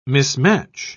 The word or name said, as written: mismatch